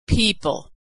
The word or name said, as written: people